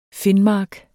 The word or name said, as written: finmark